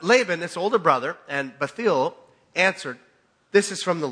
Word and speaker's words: Bethuel: How do you know how it's pronounced? Bethuel. No. Bethuel, yeah, answered.